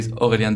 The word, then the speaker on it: Aurélien: Yeah, it's it's I would say the correct Czech pronunciation for my name. If I if I pronouncing the French way, Aurélien Deves.